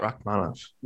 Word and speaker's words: Rakhmonov: Rakhmonov, right? Is that how you pronounce it? – Yeah, Shavkat Rakhmonov.